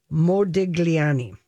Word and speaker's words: Modigliani: Modigliani.